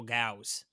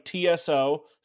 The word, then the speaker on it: Tso: So sometimes it'll be pronounced like TSO, sometimes it'll be GAO, and there may be a couple other versions of it too.